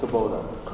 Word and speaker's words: Kubota: C, entertain a motion to approve purchase of a 2018, is that pronounced Kubota? – Kubota.